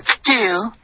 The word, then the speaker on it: still: But still, it's a big change. The end of the narrow. – Okay, 这个 still 的这个发音，第一，你的元音呢，再用一个 du 的这个音，你没有再用一个 de 的这个音，然后你的 l 的音呢，你也在就是用靠嘴唇，那这个就导致你的发音就变成 du。